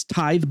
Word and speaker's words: tithe: It's tithe barns. That's how it's pronounced. Some people say like tithy, and I always kind of giggle when I hear that because it just sounds really different.